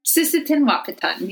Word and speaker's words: Sisseton Wahpeton: Did I say it right? – Sisseton Wahpeton, yeah.